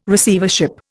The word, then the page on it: Receivership: Indian